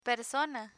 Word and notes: persona: Spanish

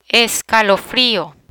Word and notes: escalofrio: Spanish

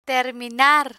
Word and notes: terminar: spanish

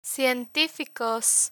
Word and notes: cientificos: spanish

